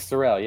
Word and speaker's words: Sorrell: Yeah, Nick Sorrell.